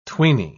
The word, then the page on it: tweeny: British English